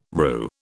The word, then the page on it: RU: British English